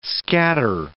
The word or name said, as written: scatter